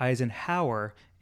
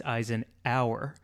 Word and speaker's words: Eisenhour: I'm going to spell it because it's not Eisenhower, it's Eisenhour sculpture. So it's E I S E N H O U R, and then sculpture.